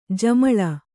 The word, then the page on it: ಜುಮಲಾ: kannada